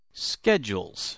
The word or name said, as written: schedules